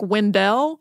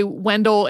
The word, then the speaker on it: Wendell: Also, I wanted to note that there are people who knew him who pronounced his name more like Wendell, but the vast majority seem to say Wendell, including some interviews with his children. So if at some point you're watching old TV footage about Wendell Scott and you're like, why did they say Wendell? That's Wendell seems to be the more common.